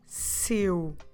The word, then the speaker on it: seu: And one is with the possessive seu. Seu.